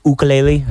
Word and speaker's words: ukulele: I mean, he was good with his ukulele, or his ukulele. I think that's the right way you're supposed to say it. Yes, it is. That's how it's actually pronounced.